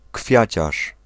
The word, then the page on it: fleuriste: France; [flœʁist]